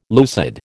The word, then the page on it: lucid: American English